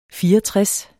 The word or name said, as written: fireogtres